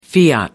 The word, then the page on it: fiat: American English